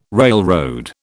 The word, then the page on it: railroad: British English